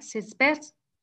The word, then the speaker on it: sitz bath: Marlene told me in comments, she told me I have a sitz bath. Did I pronounce it right? Sitz bath? – Sitz bath, yes. Yes, that's right.